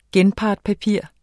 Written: genpartspapir

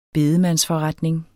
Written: bedemandsforretning

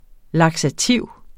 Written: laksativ

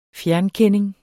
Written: fjernkending

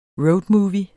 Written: roadmovie

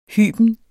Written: hyben

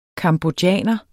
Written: cambodjaner

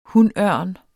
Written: hunørn

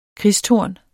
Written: kristtorn

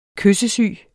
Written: kyssesyg

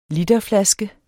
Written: literflaske

